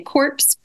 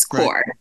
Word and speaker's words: Corps: And a lot of people mispronounce it by saying corpse, but it's core. Right. A silent S there.